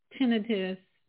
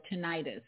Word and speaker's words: tinnitus: So that was a very general summary of tinnitus, ringing in the ears, or tinnitus. It's two ways they pronounce it.